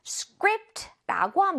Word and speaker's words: script: 뭔가의 원고나 대본을 보고 우리는 script라고 합니다. 우리말로 그냥 스크립트 스크립트라는 것도 여러분 한번 들어보셨을 것 같은데 영어 발음은 script. Script. Script. 그렇게 하시면 돼요.